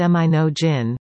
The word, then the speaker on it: Monongahela: In December of 2022, the Monongahela greenhouse, pronounced Minogin, with a hard G, had just been placed at Jocko Point, still waiting for the final setup.